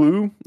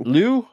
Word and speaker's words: Liu: Simu Lee. I might be mispronouncing his name. – I feel empowered now. – No, I think you're right. – Yeah. – Simu Liu. Is it Simu Lee or Simu Liu? It's spelled L-I-U. – Liu? Oh, maybe it's Liu.